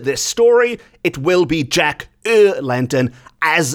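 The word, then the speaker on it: Jack y Lantern: The O is replaced with a Y, and in Welsh, Y means the, and lantern is spelt as lantern is spelt in English. So we end up with a name which is Jack the Lantern. Jack er Lantern.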